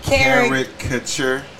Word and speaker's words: caricature: And they would kind of do a character caricature. Uh, I'm saying that wrong. – That's Car- that word. – caricature. – Yeah, caricature. Yes.